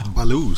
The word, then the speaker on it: Beaulieu: Nick Beaulieu. – Baloo säger Felix att han brukar kalla sig själv så vi får väl säga Baloo.